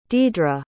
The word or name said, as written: deidre